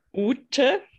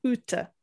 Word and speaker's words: Ute: and uh, my German name is Ute. Ute. U-T-E.